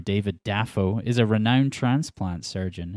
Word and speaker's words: Dafoe: His real name's William, and he says half of his family pronounce his surname with the stress on the first syllable. So before starting acting, he was William Dafoe.